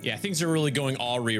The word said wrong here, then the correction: awry: right now. I mean, awry. Shoot. – You put the emphasis on the wrong syllable.